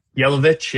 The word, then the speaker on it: Jelovic: Is it Jelovic? – Jelovic, yeah. – It is Jelovic. Because okay, I wasn't sure if it was a silent J or not.